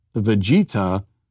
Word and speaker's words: Vegeta: And finally, Raptor Jesus says, I do love the commenter Prince Vegeta's name. It's Vegeta. He's from Dragon Ball Z.